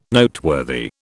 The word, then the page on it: Noteworthy: British English